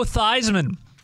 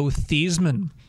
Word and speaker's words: Theismann: Actually pronounced Joe Theismann. – So, reason is, when he got to Notre Dame, they said, we want you to change the pronunciation of your name because the new pronunciation would rhyme with Heisman, it would help your Heisman Trophy campaign.